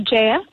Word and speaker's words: Jaya: It's actually Jaya, but very close.